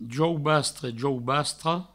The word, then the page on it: jaubastre, jaubastra: jaubastre, jaubastro; Provençal